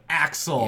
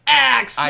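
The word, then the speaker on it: A-X-L: We're showing Axel. – Yes. – I don't know if you're supposed to say it like Axel. – I think you have to, That's A.X.L.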